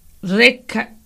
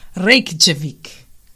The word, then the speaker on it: Reykjavik: Reykjavik. – Okay, you could really, really spell it. – R-E-Y-K-J-A-V-I-K. Reykjavik.